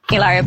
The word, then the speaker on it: Hilaria: Hilaria Baldwin.